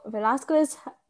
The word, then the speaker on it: Velázquez: So Velázquez, to practice my proper Spanish pronunciations.